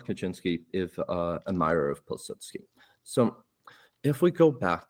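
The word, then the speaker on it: Piłsudski: So I was going to ask you, what is the relevance of Pilsudski? I think I said that right. My Polish pronunciation. – Yes, Pilsudski.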